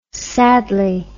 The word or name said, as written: sadly